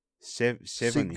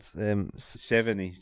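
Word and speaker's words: Sevigny: Seveny. – Oh my God. – Seveny. You don't say the G. I knew something was silent. It's Chloe Seveny. Seveny.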